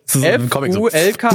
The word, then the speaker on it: Fulk: F U L K Fulk.